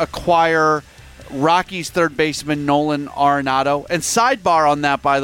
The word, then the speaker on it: Arenado: And she would say Arenado.